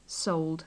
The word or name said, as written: sold